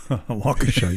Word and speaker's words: Waukesha: Waukesha? – Yeah, Waukesha, yeah.